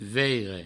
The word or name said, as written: veire